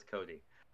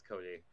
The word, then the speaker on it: Cote: Should probably actually say that it's Cody.